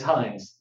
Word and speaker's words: Heins: It's Heins, like the ketchup, only spelled differently.